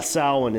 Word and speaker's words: Samhain: But yeah, Samhain is how it's pronounced.